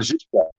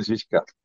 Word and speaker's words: Žižka: Jan Žižka. Did I say that correctly? – Jan Žižka. Yeah, Jan Žižka. It's correct. Yeah.